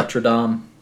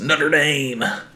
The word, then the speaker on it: Notre Dame: Because it's not Latin. Yeah, it's French. Huh. Notre Dame. But yeah, whenever you get into America, it's Notre Dame.